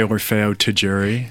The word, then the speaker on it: Orfeo Tagiuri: I say Orfeo Taguri.